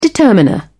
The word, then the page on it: determiner: British English